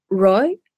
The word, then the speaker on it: Ryo: And it follows a transgender teen, Ro, I'm going to mispronounce it, spelled R-Y-O, but I know it's like a one syllable pronunciation.